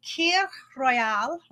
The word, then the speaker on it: Kir Royale: Called Kir Royale. It's spelled K-I-R Royale.